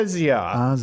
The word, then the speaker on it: Asia: It's Asia.